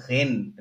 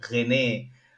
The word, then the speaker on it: Rennes: And before I say, when I was writing the questions, and I used to think the city, it's called Rennes, not René.